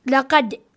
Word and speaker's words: لقد: It's like you pronounce a sound, a bouncing sound at the end, but you also pronounce a very fast time, like لقد, like you stop immediately. – Yes. – Right after you're done with the bouncing.